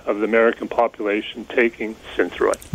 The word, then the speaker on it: Synthroid: Tell me if I'm pronouncing this right, Synthroid. – Yeah, Synthroid was,